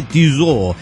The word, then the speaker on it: Tissot: Bueno, Tissot se dice Tissot y no Tissot. En ese caso se escribiría Tissote para que se pronuncie la T.